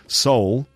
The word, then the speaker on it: Seoul: So Seoul is how we pronounce the capital city of South Korea. Seoul, S-E-O-U-L, we pronounce it soul. And if something is soulless, it means it has no soul, like S-O-U-L.